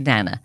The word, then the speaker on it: Nana: Then Melchior was sick down his beard, so Audrey removed him from the narrative and handed him over to his Nana, which she pronounced to rhyme with banana.